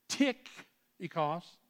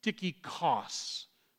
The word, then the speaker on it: Tychicus: Tychicus. Or Tychicus. Or Tychicus. And you're wondering, does anybody know how to pronounce the name? In the Greek, it is Tychicus, with the emphasis on the last syllable.